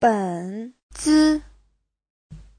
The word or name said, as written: ben3zi5